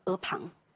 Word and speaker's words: 阿房: 中学老师告诉我们，这个字是一个特殊的专有名词，应该把它读作阿房。如果大家是在 20世纪上的中小学，那么很有可能你的语文老师告诉你，这是一个专有名词，应该读阿房宫。